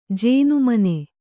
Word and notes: ಜೇನುಮನೆ: Kannada

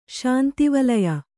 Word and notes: ಶಾಂತಿವಲಯ: śanti valaya; Kannada

